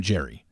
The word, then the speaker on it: Jerry: Again, I don't know why, because clearly before that, I pronounced his name Jerry. So pardon me. Sometimes I just draw a blank when I say names, and it's Jerry Turner.